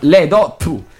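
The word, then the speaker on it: Lê Đức Thọ: To in vietnamese si pronuncia con lo sputo, quindi Le Do Tu.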